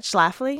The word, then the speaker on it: Schlafly: How do you say that? Schlafly? – Schlafly.